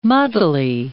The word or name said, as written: muddily